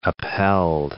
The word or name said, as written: upheld